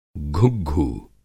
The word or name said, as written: घुग्घू